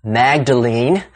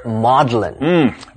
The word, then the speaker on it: Magdalene: And that famous college at Cambridge is not pronounced, as you would expect, Magdalene, but rather Maudlin.